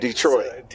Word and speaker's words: Detroit: Yeah, no, not Detroit, bro. – Yeah. – Detroit. – Detroit. – Yeah, so, yeah, that accent is on the second syllable.